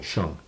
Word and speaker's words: Hsiung: How do you spell Shung? – H-S-I-U-N-G. – Yeah. And it's pronounced Shung. – Shung.